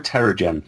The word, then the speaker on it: Terrigen: Am I pronouncing that right? Terrigan? I, I tend to go with Terrigen. Terrigen. Is that how it's pronounced in Agents of S.H.I.E.L.D.? I think so, yeah. They call it that.